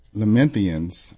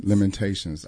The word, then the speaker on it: Lamentations: Because the Lord, and this is Lamentians. I hope I'm saying that correctly. Lamentians 3:22-23. Because the Lord's great love, we are not consumed, for his compassion never fails. They are new every morning. – Lamentations.